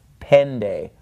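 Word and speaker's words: pende (πέντε): Remember, this tough, that looks like a little T, whenever it comes followed by the ni, it is pronounced more like an English D. So instead, it's not pente, it's pende, all right?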